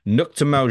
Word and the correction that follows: Neuchâtel: Jamax. – Neuchâtel? – Fuck me, James. Cheers, mate. Yeah. Neuchâtel.